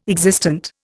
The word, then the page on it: existent: Indian